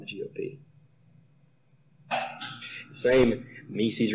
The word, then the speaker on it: Mises: The Mises Caucus. I'm sorry, the Mises Caucus, sometimes mispronounced that.